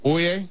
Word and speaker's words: Oia: And let me tell you, if you go to Santorini, my suggestion would be to stay in the Oie, which okay. is spelled O-I-A.